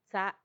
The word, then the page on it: tsa: Macuiltianguis